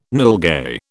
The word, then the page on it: nilgai: British English